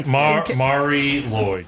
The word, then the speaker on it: Mari Lwyd: A sheet called the Mari Lwyd. I think I said that right. – Yeah, Mari Lwyd. Yes.